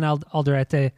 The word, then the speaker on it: Alderete: But Juan, I think I'm going to mispronounce this because I'm not good at this, but Alderete, is that? I'd say that's right. Juan Alderete.